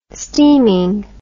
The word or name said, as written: steaming